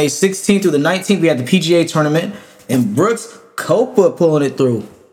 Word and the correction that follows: Koepka: I would just like to say his name is Brooks Koepka, but you know, it's fine. It's fine. – Koepka.